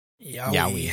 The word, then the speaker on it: yaoi: Yaoi.